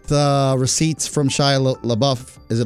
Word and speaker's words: LaBeouf: And LaBeouf is pronounced LaBeouf. It means in French, it means the beef.